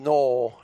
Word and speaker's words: Noel: Now, it is pronounced Noel, not Noel, but hey, people have been mispronouncing my name for all my life, so what the heck. Even Siri gets my name wrong.